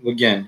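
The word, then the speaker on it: Gauguin: Γκογκάν διαβάζεται, δεν ξέρω πώ πώς ε, προφέρεται σωστά. Γκογκέν, okay.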